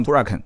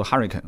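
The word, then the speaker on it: Huracán: 后来我想了一下，其实兰博基尼确实也没什么可拍的，因为一辆敞篷，一辆 Huracan 啊，你们之前说我发音不对，我说 Huracan 啊，有 Huracan 啊，Huracan 啊，反正我肯定不读 Huracan，我不管谁说这个乌的发音是准的，反正我打电话给兰博基尼官方，他就这么念的啊。这个兰博基尼跟我之间发生不少事啊，不让进。不让进，我看了一下子，一个 Huracan 的 Performance 一个这一款啊，还有一个就是大牛的一个改款，其实改的也就那么回事。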